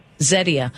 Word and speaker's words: Zetia: So how do you pronounce it? – Zetia. Z-E-T-I-A.